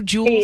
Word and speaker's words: Juul: Do Juul? – Yes.